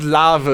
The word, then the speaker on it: Larve: L-A-R-V-E. – Es gibt nicht das Wort Larve, es gibt Lava. Natürlich. Da spricht man mit... – Ja, Lava ist auch ein gutes Beispiel. – Ja, aber Larve spricht man mit dem F.